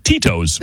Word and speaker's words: Tito's: Pronunciation, Tito's. T-E-E-T-O-E-S.